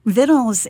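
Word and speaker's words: victuals: And my assumption, I had learned that often we are right in what we say, but I assumed that the pronunciation of Vittles was going to be an alternative pronunciation. And then I looked it up in the dictionary, and lo and behold, we have been right all along. Vittles is how the word was pronounced from the get-go, and sometime probably around the 15th or 16th century in England, they decided that they were going to Latinize a lot mm-hmm.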